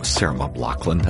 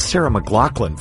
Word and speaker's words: Sarah McLachlan: Sarah McLachlan. That's Sarah McLachlan, for those of you who can pronounce an M correctly.